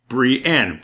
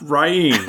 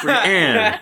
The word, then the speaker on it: Brienne: Every time I have to be like, Brienne, not Ryan, Brienne.